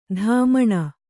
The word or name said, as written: ದಮ್ಮಣಿ